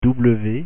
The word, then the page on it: w: French